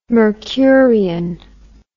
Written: Mercurian

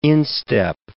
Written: instep

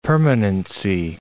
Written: permanency

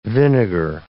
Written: vinegar

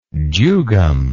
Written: jugum

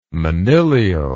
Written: monilial

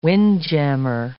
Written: windjammer

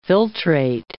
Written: filtrate

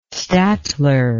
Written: statler

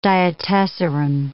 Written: diatessaron

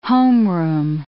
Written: homeroom

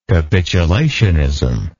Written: capitulationism

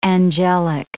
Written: angelic

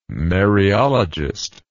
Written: Mariologist

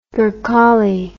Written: gurkhali

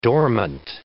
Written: dormant

